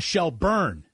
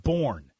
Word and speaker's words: Shelburne: Be like, remember, it's Ramona Shelburne, not Born.